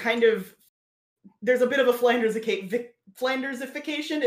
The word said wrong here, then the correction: Flanderization: is that how you say it? – Yes. – Flanderization, I think. – Flanderization.